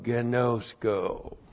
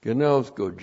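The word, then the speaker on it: Gnosko (γινώσκω): And then we have the verhina plus the present active indicative of gnosko. Gnosko, G-I-N, long O-S-K, long O, gnosko.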